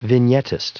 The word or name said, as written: vignettist